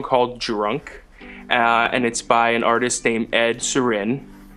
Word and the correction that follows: Sheeran: It's Ed Sheeran. You're pronouncing it pretty weird.